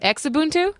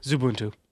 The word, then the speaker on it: Xubuntu: Xubuntu? – Zubuntu.